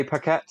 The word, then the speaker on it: Paquette: I remember it was Renee Young, but how do you pronounce her surname? – Is it Paquette. Renee Paquette? Yeah.